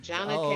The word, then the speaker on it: Jonna: It's like Donna with a J. Jonna K.